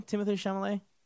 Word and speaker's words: Timothée Chalamet: Timothée Chalamet? – Yeah.